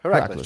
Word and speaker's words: Heracles: I think Luca was trying to cement his season to start with Heracles. I don't know how you say it. How do you say it? – Her- Heracles. Yeah, Heracles. Just like that.